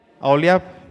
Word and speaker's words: Aholiab: ¿Cómo se pronuncia? ¿Aholiab? ¿Aholiab, Vic, se pronuncia? H-O. Aholiab.